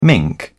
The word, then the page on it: mink: British English